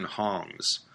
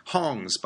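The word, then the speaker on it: Hongs (行): Thanks to the crisis, though, many Chinese firms filled the vacuum left by these failed Western Hongs. Hongs, by the way, is the Cantonese word for company. In Mandarin, this is pronounced Hong.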